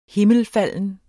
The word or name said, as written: HIMMELFALDEN